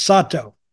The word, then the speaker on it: Sato: I think it's Sato. – It probably is Sato because just how Japanese pronounce it. – Because they flatten out their vowels.